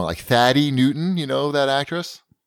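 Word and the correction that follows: Thandie: Thandi, yeah.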